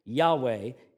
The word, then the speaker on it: Yahweh: Yahweh is the most common way to pronounce it today.